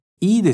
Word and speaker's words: いい: Muito importante a pronúncia do いいですよ。 Prolonga bem ele. Se falar い significa estômago. いいですよ。いいですよ。いいですよ。 Agora voltando para a nossa frase. いいですよ。